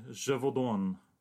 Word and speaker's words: Gevaudan: The Beast of Gevaudan.